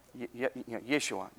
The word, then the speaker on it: Yeshua: The J sound, now in English we say Jesus, but how was the J pronounced in the actual Hebrew language? Anybody know? Starts with a Y. It's got the Y sound. So it would have been, instead of Joshua, Yeshua, yeah.